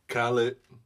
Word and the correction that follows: Khalid: I don't know. – The nigga's name is Khalid, dog. – Khalid, I'm sorry, y'all.